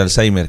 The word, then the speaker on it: Alzheimer: Alzheimer, sí.